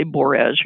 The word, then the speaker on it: borage: So it's an ornamental, and borage is kind of pronounced, it kind of rhymes with porridge. So when you say borage, you're saying porridge. You know, it's kind of similar. But borage, B-O-R-A-G-O, borage officinalis is its genus name.